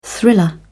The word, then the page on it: thriller: British English